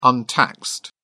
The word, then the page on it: untaxed: British English